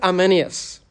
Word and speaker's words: Arminius: The Remonstrants were followers of Jacob Arminius. That's the English pronunciation. I won't try the Dutch.